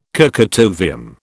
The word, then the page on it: kurchatovium: British English